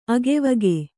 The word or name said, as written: ಅಗೆವಗೆ